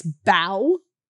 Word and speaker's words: bow: They load another collapsible A into the water, but the Titanic's bow. – Yeah. – Bow, right? – Yeah. – It's spelled B-O-W, guys. – So B-O-W?